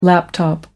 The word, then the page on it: laptop computer: British English